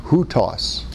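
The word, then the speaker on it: houtos (οὕτως): But in the Greek that John wrote, he often uses a word that's pronounced houtos, which means like this.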